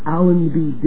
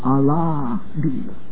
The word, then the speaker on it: Allenby: And what Allenby didn't know is that the Muslims were mispronouncing his name. And instead of it being Allenby, they were pronouncing it Allahby.